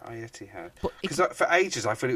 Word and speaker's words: Etihad: The Etihad. – How do you say that? How do you spell it? – I don't know how you spell it. It's pronounced the Etihad.